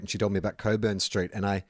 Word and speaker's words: Cockburn: But, yeah, I mean, anyone who's got the last name Cockburn knows what we're talking about, and they've pronounced it Coburn their entire life. When your name is actually Cockburn, we all see it written down, but I understand also why you don't want your last name pronounced Cockburn.